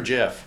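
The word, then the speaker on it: Geoff: It's spelled G off, so out of habit, I said G off. It's Jeff.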